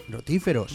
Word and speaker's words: rotíferos: Hay unos seres que se llaman los rotíferos. – ¿Rotíferos? – Rotíferos, con el acento en la I. Rotíferos.